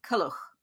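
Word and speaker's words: Culhwch: So probably not so much kol as kol. So kuluch.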